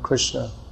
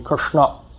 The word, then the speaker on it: Krishna: And most of us don't pronounce Krishna properly. It should be Krishna.